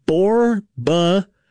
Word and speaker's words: borborygmus: The scientific word for your stomach rumbling is pronounced borborygmus. Borborygmus.